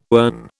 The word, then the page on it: earn: British English